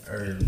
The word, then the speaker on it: herbs: Herbs. – Wow. Nah, fuck that. – The H is silent. – We gonna say it like how it's spelled.